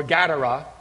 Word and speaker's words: Gadara: Now, previously, in his encounter with the demoniac of Gadara, or Gadara, as it's properly pronounced, Jesus proved his authority over the powers of demonic forces by casting the demons out of this poor man.